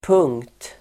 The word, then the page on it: punkt: [pung:kt]